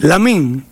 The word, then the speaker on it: Lamine: Con gol de Lamine Yamal, a los dos minutos puso Lamián a ganar. – Lamine. Se escribe Lamine Yamal.